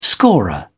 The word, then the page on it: scorer: British English